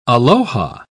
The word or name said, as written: aloha